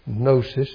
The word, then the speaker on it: gnosis: Gnosis is spelled with a G, but it's gnosis.